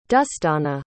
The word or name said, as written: dustiauhanna